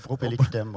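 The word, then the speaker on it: Pro pelle cutem: Il a publié quatre recueils de poèmes, tous aux éditions du Passage Géographie de l'ordinaire, L'héritage du mouvement, Propelicutem, je ne sais pas si je le prononce. Non, c'est parfait, Propellicoutem, oui.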